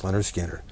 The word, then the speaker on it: Lynyrd Skynyrd: Lynyrd Skynyrd, pronounced Lynyrd Skynyrd.